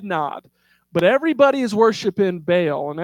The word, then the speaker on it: Baal: We don't typically like the way that Baal is pronounced in Hebrew. It's actually pronounced Baal, but that kind of sounds weird. Doesn't flow. So I'm going to say Baal, but I just want to educate you that it's actually Baal.